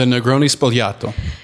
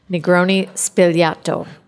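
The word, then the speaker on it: Negroni Sbagliato: The Negroni Spogliato. – Negroni Spogliato.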